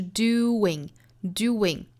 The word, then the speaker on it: doing: 好，不要发成我们中式的样子 being 就不对了，应该是 being being，非常轻快的一个小小的 ye， being being being，并且呢重读是在第一个音节上，我们把这个节奏读出来是 dada， being being，后面呢是 doing doing，后面这个音节更像是一个 wing 这个词，就 wing 翅膀这个词，但是我们一定不要读成真正的十足的翅膀这个词，因为首先这个发音是在这个词里面的弱读音节上，而且呢我们要知道加的这个小小的 w 是非常轻快的， doing doing。